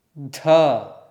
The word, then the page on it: dh: Sanskrit